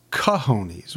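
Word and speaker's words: cojones: Well, it's pronounced cahonies. – That's what I said. – I thought you said gahonies.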